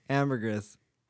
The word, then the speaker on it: ambergris: Ambergris is French for amber. – Yeah. And as the French often do, the IS.